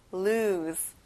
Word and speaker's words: lose: 끝에 S 발음을 Z 발음이랑 비슷하게 해보는 거예요. lose.